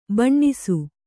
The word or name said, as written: ಬನಸಿ